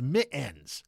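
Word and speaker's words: Mi'ens: They said it's pronounced Mittens, like mittens, but without the T. So it's actually more of a kind of a guttural or glottal stop, like Cockney.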